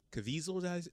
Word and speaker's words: Caviezel: The thing is we're talking about Jim Caviezel. Is that how you say his name? Mm-hmm.